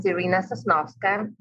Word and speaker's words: Iryna Soznovska: My name is Iryna Soznovska.